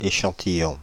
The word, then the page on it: extract: American English; /ˈɛkstrækt/; /ɪksˈtrækt/